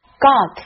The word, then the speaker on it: got: Got 和 lot 结尾的 t 这里被浊化成了 d。